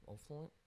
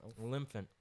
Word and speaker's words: Olyphant: O'Flint? – Olyphant.